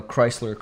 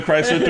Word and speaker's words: Chrysler: Chrysler's the You don't pronounce the H in Chris or Chrysler. They're both CR.